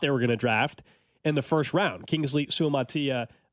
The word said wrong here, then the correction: Suamataia: I just butchered his name again. I'll get it down one of these days. Suamatia? I think that's it's right. I mean, yeah. Suamatia. Matatia. Matatia. – Kingsley Suamataia.